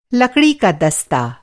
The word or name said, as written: दस्ता